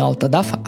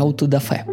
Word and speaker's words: аутодафе: Слово самым уродским ударением на свете. Не аутодафа, а аутодафе.